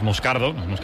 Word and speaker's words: Moscardo: Como bien ha pronunciado Unai es Moscardo, es brasileño, sí, sí.